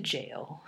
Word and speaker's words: gaol: to jail, which is spelled G-A-O-L. – Oh, nice, like in Ireland.